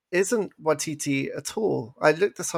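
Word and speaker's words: Waititi: His name is pronounced Watiti. – What did I say? – Watiki. You said it twice now.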